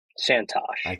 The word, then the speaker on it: Santosh: I think I mispronounced you. It's Santosh, right? – Santosh. – Santosh. Like Tosh.